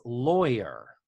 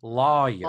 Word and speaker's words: lawyer: And pretty much everywhere in the country, other than the South, says lawyer. Loy, like rhymes with boy. But in the South, Lisa Welchel, being from Texas, they say lawyer. – Lawyer. – Like my lawyer practices law. The two words are pronounced the same.